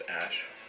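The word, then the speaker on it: Æ: and then the AE is like pronounced Ash.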